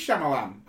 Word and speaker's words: Shyamalan: The end, there was a twist, and it was like an okay Shyamalan film. Like, it just... Is there a Shyamalan? – Is there such thing as an okay Shyamalan film? – Yeah. – M. Night Shyamalan? Shyamalan? – It is Shyamalan.